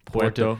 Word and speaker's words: Puerto: I said Porto. Puerto. Puerto. Yeah.